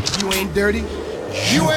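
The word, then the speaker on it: Dirrty: And you actually did not pronounce it correctly. It's Dirty. – Wow, yeah, those extra R's. You're right. – She puts two R's in there, yeah.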